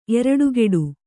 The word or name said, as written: ಎರಟೆಕುಟ್ಟಿ